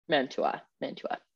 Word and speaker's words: Mantua: So this consortium verifies that producers from the Reggiana region or the provinces of Parma, Reggio Emilia, Modena, Bologna, and Mantua, I think that's how you say it, follow established protection rules. – Mantua.